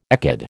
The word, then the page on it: ecad: American English